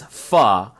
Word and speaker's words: pho: Well, this triggered Justin because the correct pronunciation is pha. Pha, F-A-H, pha, not fa, F-A-H, like fa la la la la la la la la.